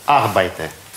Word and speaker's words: Arbeite: Arbeite. – Arbeite nicht. – Nicht. – Nicht. Du arbeitest. – Это ударение на первое.